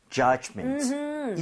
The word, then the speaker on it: judgment: D 사운드는 굳이 세게 낼 필요가 없어요. 그래서 그냥 judge, judgment 이 정도도 충분합니다. – 네. – 오케이? 자, 그러면 judgment 발음 한번 해볼게요. D 사운드 크게 내지 마시고요. 가보겠습니다. Judgment. Judgment. – Judgment. – 오, Judgment. 훨씬 좋잖아요.